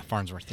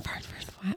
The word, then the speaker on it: Farnsworth: Yeah, Farnsworth. Yeah. Farnsworth. – I want to say farm with an M, but it's not.